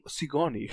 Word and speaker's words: SIGNORE: Ja, der, der Computer heißt, äh, hat abgekürzt S-I-G-N-O-R-E, ausgesprochen Sigourney.